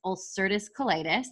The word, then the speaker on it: ulcerative colitis: So I would love to kind of touch on, you have a chronic illness, ulcerative colitis.